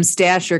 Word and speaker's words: Stacher: So it is S-T-A-C-H-E-R. That's how it's spelled, but it's pronounced Stacker. – Got it. – Like it's Anglified German.